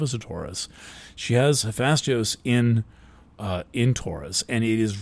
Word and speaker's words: Hephaestus: Hephaestus, it must be Hephaestus is the way to pronounce that.